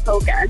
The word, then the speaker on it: polka: Do you pronounce the L? – No, I do not. I call it polka.